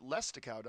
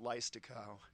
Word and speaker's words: Leistikow: I think you pronounce it Lestikow, don't you? Lestikow. Okay. Well, because of the I after the E, I always pronounced it Leistikow.